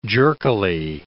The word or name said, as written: jerkily